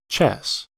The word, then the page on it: chess: American English